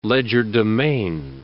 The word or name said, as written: legerdemain